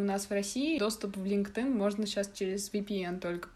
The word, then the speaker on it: LinkedIn: Во, LinkedIn. LinkedIn, да, он произносится.